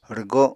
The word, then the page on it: rwgo: Apatani